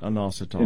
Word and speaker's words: inositol: Well, I call it inositol. – Inositol. Inositol, that's it.